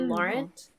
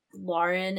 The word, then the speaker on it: Lauren: I get Laura, or like, I don't care, but technically like Lauren is the right way to pronounce it versus Lauren.